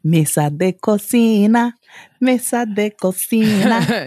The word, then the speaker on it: cocina: How do you say kitchen? Mesa de... Is that? Yeah. Mesa de cocina.